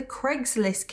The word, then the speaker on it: Craigslist: And listen, guys, I know it's pronounced Craigslist, but I'm British and we pronounce Craig as Craig. So please excuse me if sometimes I say Craigslist instead of Craigslist.